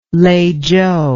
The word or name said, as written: Leichou